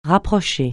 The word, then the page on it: rapprocher: francais